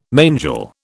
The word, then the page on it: mangel: British English